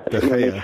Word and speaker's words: De Gea: De Gea, or however you know how to pronounce De Gea. – Okay, thank you.